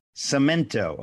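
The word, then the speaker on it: Samento: Is it C-I-M? – No, it's S-A-M-E-N-T-O. – Cemento.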